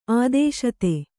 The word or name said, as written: ಆದೇಶತೆ